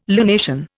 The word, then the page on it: lunation: Indian